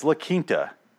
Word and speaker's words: La Quinta: And then we stopped at the La Quinta Inn, and I'm like, it's La Quinta. It's La Quinta Inn. It's a fucking Spanish word. You don't pronounce the Q.